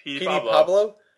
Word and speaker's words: Petey Pablo: Petey? Is that Petey? – Yeah, Petey Pablo.